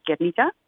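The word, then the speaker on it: Gernika: So the Basque pronunciation of the town is Guernica.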